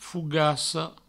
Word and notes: fogaca: fougasso; Provençal

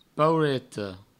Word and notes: pauretat: paureta; provencal

